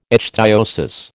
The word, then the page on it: ichthyosis: American English